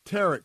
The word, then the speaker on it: tarot: I call them tarot cards. It's spelled T-A-R-O-T, emphasis on rot.